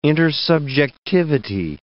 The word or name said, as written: intersubjectivity